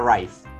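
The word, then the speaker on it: Reiff: Me busqué la pronunciación para ver a ver cómo era y Google me dijo que era Rife. Entonces yo le digo Rife.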